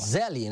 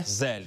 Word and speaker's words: Zelle: Zelle, né? – Zelle.